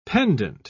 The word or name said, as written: pendant